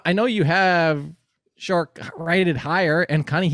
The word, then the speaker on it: Shark: Oh, by the way, this apparently, if you look at his pronunciation, it's supposed to be Shark, like the animal.